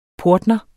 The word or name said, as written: PORTNERES